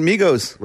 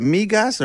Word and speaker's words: Migos: Is it Migas or Migos? – Well, they're not. They're Migos. – They're dead. – Yeah, Migas is what you eat.